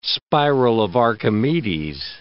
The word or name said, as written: spiral of Archimedes